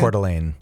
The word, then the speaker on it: Coeur d'Alene: Coeur d'Alene.